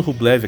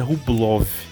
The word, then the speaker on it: Rublev: Aliás, eu estou falando Rublev aqui, a pronúncia do nome dele é Rublov. É difícil explicar, mas é uma coisa do caractere do alfabeto russo. É mais ou menos o Rublov pelo mesmo motivo que o Gorbachev, se falava Gorbachev apesar de escrever Gorbachev.